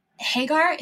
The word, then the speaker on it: Hagar: And you talk quite a bit about Hagar. Is that how you pronounce it? – Mm-hmm.